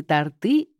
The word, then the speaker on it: торты: Вы любите торты или торты? Торт – здесь слово «ориентир». Оно имеет фиксированное ударение, неизменное во всех падежных формах или числах. Торты – тортов.